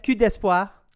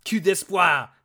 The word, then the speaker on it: Coupe d'Espoir: That was okay. Coupe d'Espoir. – Coupe d'Espoir. That's how I should say it.